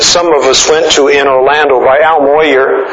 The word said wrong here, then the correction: Mohler: Al Mohler, president of Southern Seminary.